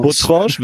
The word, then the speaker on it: Botrange: Wenn ich nur einmal in die, ja, Botranche Botrange wird ausgesprochen, okay.